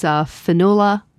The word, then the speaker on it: Fionnuala: It's Fionnuala, I believe is how you pronounce it, Flanagan.